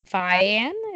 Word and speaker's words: Fian: In 1590, John Fian is how I'm going to pronounce that name. – Sounds good. – F-I-A-N, confessed under torture during his witch trial in Scotland to breaking into a church using a Hand of Glory.